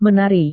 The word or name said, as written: menari